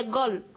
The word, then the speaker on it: goal: Donc, comme c'est volleyball, là, on prononce en anglais goal.